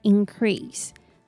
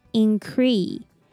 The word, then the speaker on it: increase: The first word, increase. The first IN sounds like ing, ing. And the C pronounces k. And ER is E, increase, increase. Unless we add S, increase. And remember to stress the second syllable.